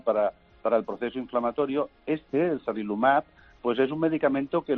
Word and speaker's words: sarilumab: Estáis trabajando con un ensayo, con una cosa que se llama sarilumab.